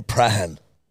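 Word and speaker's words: Prahran: It's spelled P-R-A-H-A-N, Prahan.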